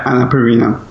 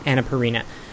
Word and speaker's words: Annapurna: Can you tell me, how do you pronounce Annapurna? Is that the name of a publisher? – Yeah, Annapurna. – Annapurna.